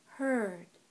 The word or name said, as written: heard